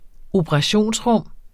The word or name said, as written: operationsrum